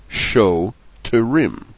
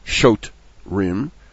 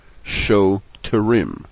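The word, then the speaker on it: show terim (שוטרים): Instead, it begins the second syllable, fetim, show fetim, followed by the show terim, not shot rim. Shoterim. Shofetim are judges.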